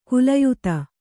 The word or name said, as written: ಕುಲಯುತ